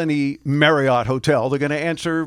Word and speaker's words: Marriott: And, for years we've all been saying Marriott. Well, it's not. It's Marriott. Rhymes with chariot.